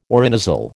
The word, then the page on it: orinasal: American English